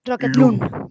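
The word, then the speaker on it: Rocketloon: Rocket Loon. Rocket Loon se pronuncia. L-O-O-N.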